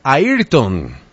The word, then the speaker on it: Ayrton: O sea que tú eres Ayrton. – Exactamente, tal y como lo pronunciaste, está correcto.